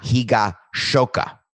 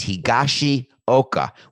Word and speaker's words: Higashioka: So he sent me something after the show last week, Friday, and said, come on, Sampson, out of respect to Ichiro, you have to pronounce Japanese names correctly. The Yankees have Kyle Higashioka, not Higashoka. It's Higashioka, which means Eastern Hillside.